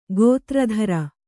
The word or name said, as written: ಗಾತ್ರತ್ರ